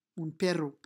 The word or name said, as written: un perro